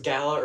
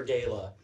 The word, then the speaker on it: gala: I'm like, is it pronounced gala or gala? – Fine, whichever you want. – I've heard both. – So. It can be whatever you like. – I've heard it both ways.